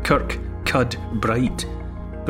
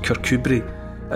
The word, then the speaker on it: Kirkcudbright: It's Kirkcudbright, but it's pronounced Kirkcudbright, and it basically means the Church of Cuthbert.